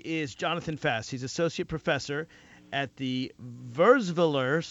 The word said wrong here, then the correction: Wurzweiler: Did I pronounce that right? – Very close. It's Wurzweiler. – Wurzweiler. Okay, no, I massacred it, but that's all right.